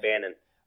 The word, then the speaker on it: Bannon: And the cool thing is, this cartoon actually aired in prime time, and you had Dr. Benton Quest and Race Banyan. Is it Bannon or Banyan? I think I've been saying it wrong this whole time. I think it's Bannon. – It's Bannon.